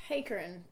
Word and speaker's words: Heeckeren: Heeckeren.